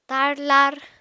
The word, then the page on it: tarlar: Apatani